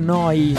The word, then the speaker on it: Neu: Questo è Polaroid, questa è Noi Radio, neuradio.it, si scrive Neu, si pronuncia Noi alla tedesca.